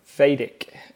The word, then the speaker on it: Vedic: So I hope I'm pronouncing this correctly, but Vedic meditation is the sort that you delve in, correct? – Yeah, so we call it Vedic.